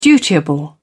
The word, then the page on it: dutiable: British English